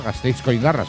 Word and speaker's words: Gasteizko Indarra: Me pienso yo que será Gasteizko Indarra, ¿no? Fuerza desde Vitoria, Gasteizko Indarra será. Que me corrijan los dominadores, los escaldunes. Osondo, me dice Jorge Benítez, lo cual quiere decir que lo he dicho bien.